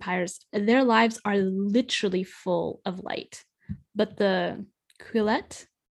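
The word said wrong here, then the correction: Quileute: I read this word more than I try to say it. – It's pronounced Quillute.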